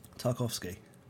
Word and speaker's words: Tarkowski: So, Bern Leno basically dropped the ball on, I think it was Tarkowski? How did you say it? – Tarkowski. That's how Sean Dyche said it, yeah.